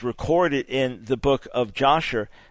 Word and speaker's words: Yashar: What is your opinion on the book of Yashar, normally pronounced Yashar, but in Hebrew Yashar, and its reliability?